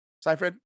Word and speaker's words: Seyfried: Seyfried.